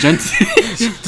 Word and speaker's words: Benty: Okay, why'd you say it like that? It is Benty.